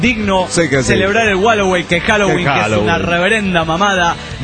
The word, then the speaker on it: Halloween: Halloween se pronuncia Halloween y es en perfecto inglés. Y no Wallaway, como tú dices.